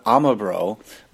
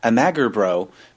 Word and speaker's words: Amagerbro: But the first few days I was saying it Amagerbro. Because that's how it's spelled.